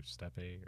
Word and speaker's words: steppe: Yeah, but the native habitat is the Eurasian steppe, or Steppe, or how do you say it? S-T-E-P-P-E. – I think it would be Step. – Steppe. – Steppe. No, it's not Steppe. I'm pretty sure it's Step.